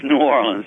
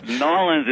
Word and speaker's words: New Orleans: I was educated by the nuns, so it's New Orleans. Nolens is kind of like the public school uneducated way of saying New Orleans, but it's stuck, so everybody says New Orleans now.